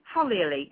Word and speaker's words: Holily: Nearly, Holily. It's close enough, and I think it's, yeah, it's, it's good enough. – The accent is the wrong on the wrong syllable.